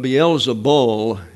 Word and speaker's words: Beelzebul: But Beelzebul is the correct spelling and pronunciation.